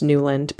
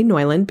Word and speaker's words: Neuland: If you look at it, it's spelled Neuland. So if you are an English-speaking American, you might think it's pronounced Neuland, but it's actually Neuland because it's a German word, Neuland. It's based on a typeface, also called Neuland, designed by Rudolf Koch, K-O-C-H, not to be confused with the Koch brothers, who are in the news nowadays.